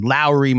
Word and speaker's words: Lauri: It's not Lauri Markkanen. My mom's name is Lori, so I'm pronouncing it Lori. But you said Guy Lowry, like he rhymes with Kyle Lowry. And so I will now just say that from now on. Lauri Markkanen. I'll just say Kyle Lowry's last name and Lauri Markkanen's first name.